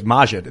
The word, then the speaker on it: Maajid: I'm very glad you guys, Seamus and Maajid, you guys, Maajid. Is it Maajid or Maajid? – Both work. – Oh, cool. – One is, Maajid is the Urdu pronunciation, and Maajid is the Arabic pronunciation.